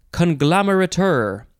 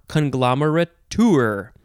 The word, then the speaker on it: conglomerateur: The first word is conglomerateur, or conglomerateur, or conglomerateur.